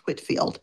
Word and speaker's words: Whitefield: His name is spelled Whitefield, but it's pronounced Whitfield.